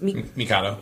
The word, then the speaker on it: Mikado: How would you actually pronounce that in Japanese? – Mikado. – Mikado.